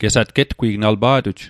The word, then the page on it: getgwi'g: Mi'gmaq